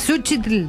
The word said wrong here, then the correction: Cempasúchitl: Por ahí vas, pero no se dice así, flaca. – Si te la chuc. – Selpazuchitl. – Cempasúchitl.